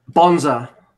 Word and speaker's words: Bonza: Bonza.